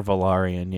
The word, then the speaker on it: Velaryon: And he was explicitly like calling out Rhaenyra's kids for not being of Velaryon. Is that how you pronounce their last name? – They're Velaryon. Yeah.